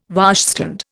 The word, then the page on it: washstand: Indian